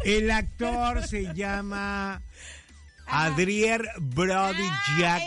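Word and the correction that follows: Adrien Brody: Adrian Brody es Adrian.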